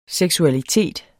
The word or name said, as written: seksualitet